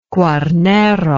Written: Quarnero

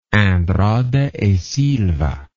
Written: andrada e silva

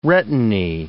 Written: retinae

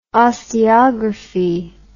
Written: osteography

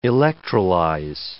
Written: electrolyze

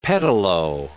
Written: pedalo

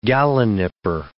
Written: gallinipper

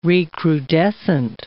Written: recrudescent

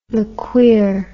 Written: lequear

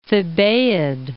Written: Thebaid

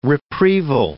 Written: reprieval

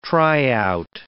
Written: tryout

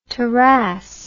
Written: tarras

